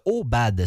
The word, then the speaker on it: Eaubad: C'est le cas de Obad. Ça s'écrit E-A-U-B-A-D. Je l'ai découvert grâce à Filigrane. Je pense que Obad